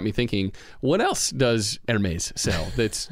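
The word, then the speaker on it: Hermès: And same thing is true with this Hermes envelope. Was that good? Good pronunciation? – That was pretty good.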